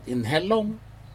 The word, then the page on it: inhelog: Aneityum